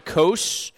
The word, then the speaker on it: Kos: Greek is their long O sound, so we'll pronounce it as that. Kos here again on this.